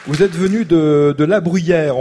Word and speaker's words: La Bruguière: Ça s'écrit La Bruguière, mais ici on dit plutôt La Bruyère.